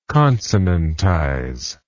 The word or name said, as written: consonantize